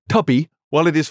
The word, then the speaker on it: Tubi: So, how do you spell it? – T-U-B-I. Tubi. – Like Mubi, but Tubi. – Tubi.